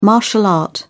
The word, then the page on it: martial art: British English